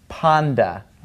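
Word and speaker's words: panda (πάντα): The letter Toph is pronounced with a T sound, a T sound, but when it has knee in front of it, it becomes a D sound, like dog in English, all right? So this word is actually pronounced panda, all right?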